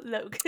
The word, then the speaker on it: Logue: See, not Rogel, Logue.